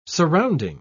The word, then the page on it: surrounding: British English